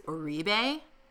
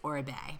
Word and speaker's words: Oribe: I've been saying it wrong until today. I thought it was Oribe, and it's Oribe.